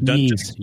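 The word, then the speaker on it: Ys: These are the confirmed games: R-Type, New Adventure Island, Ninja Spirit, Yes Book 1 and 2, or Yeez, or how do you say it? – Yeez. – Yeez. Okay. Of course. – Yeah, like Kanye.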